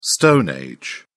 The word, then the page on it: stone age: British English